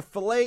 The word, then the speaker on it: fillet: We have steadfastly resisted their punctuation, and when we order a fillet, it rhymes with skillet.